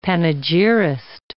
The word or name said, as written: panegyrist